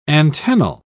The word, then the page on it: antennal: British English